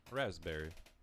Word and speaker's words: raspberry: Raspberry. Is it raspberry or raspberry? – I think the P is silent. – It's silent.